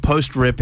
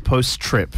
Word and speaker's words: Post-Trip: It's not post-rip, it's post-trip, which is, of course, the last track on the new EP by Dads in the Park.